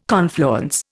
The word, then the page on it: confluence: Indian